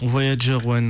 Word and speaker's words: Voyager One: One ou Voyager 1, comment tu le prononces? – Voyager One, moi je dis. – Ok, voilà, très bien, comme ça on est tous d'accord.